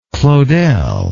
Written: claudel

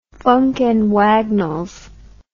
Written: Funk and Wagnalls

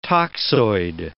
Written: toxoid

